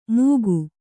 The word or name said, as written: ಮೂಕು